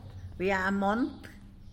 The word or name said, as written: bya mont